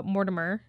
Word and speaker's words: Mortimer: Okay, so young Sophie was Emily Mort- uh, Mortimer.